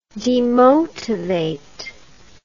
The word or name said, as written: demotivation